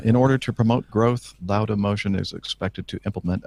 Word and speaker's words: Lauda: Lauda motion or Lauda motion? How would you pronounce Lauda. – that? Lauda. – As, as Lauda.